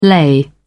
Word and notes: lay: British English

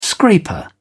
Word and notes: scraper: British English

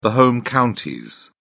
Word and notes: home counties: British English

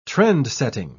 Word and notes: trend-setting: British English

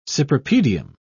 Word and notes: cypripedium: British English

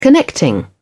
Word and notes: connecting: British English

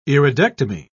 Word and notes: iridectomy: British English

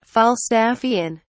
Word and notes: falstaffian: British English

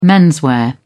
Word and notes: menswear: British English

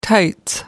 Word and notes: tights: American English

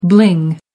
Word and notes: bling: American English

